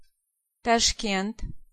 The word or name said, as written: Tashkent